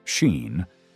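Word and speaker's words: Sín: At its heart, the story remains a supernatural narrative about the otherworld woman Sin, whose name is pronounced roughly like Sheen, and her attempt to seduce and destroy the King of Tara.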